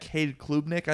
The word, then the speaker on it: Cade Klubnik: But I don't like what their offensive coordinator said after the scrimmage this weekend, where they will be getting their five-star quarterback, Cade Klubnik, I think is how you say his name. – Yep.